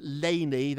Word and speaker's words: Leni: And it was so exciting to have Leni, that's how you pronounce her name, Leni on, on the cover with her mom.